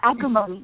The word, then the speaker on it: Agrimony: So tell us about, did I pronounce it properly? Agrimony? Agrimony, yes. It's a flower.